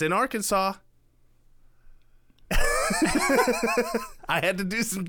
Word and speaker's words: Arkansas: It's pronounced Arkansas. – Arkansas, yes. You can't say it right.